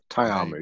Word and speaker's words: Ta'amu: Ta'amu, yeah.